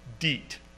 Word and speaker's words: Diet: The problem with trying to be too correct here is that the word D-I-E-T in German is going to be pronounced DEET.